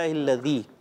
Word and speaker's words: الذی: الحمد للہ الذی اطعمنا, اور دال کو زا نہیں ہونا چاہیے, تو کوشش کریں ادائیگی بھی اچھے انداز سے ہونی چاہیے.